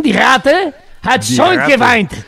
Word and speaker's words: Die Ratte hat schon geweint: Die Ratte hat schon geweint. – Muy bien. Impecable tu pronunciación.